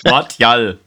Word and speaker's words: Martial: Martial. aber den hast du aber richtig ausgesprochen.